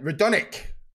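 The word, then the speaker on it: Radonjić: I've never understood the silent J. – Yeah, that though. – Radonjic. Oh, you probably don't even say it, do you? It's maybe silent. Radonic. We'll go with that.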